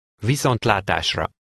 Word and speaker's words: Viszontlátásra: Viszontlátásra.